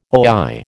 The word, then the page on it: oi: American English